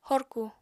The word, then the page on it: horku: apatani